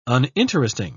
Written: uninteresting

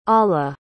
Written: alla

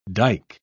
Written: dyke

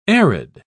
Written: arid

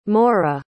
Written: Maura

